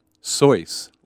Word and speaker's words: Seuss: And it's actually not pronounced in his native Seuss. It's Seuss, like rejoice.